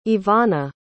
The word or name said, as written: evana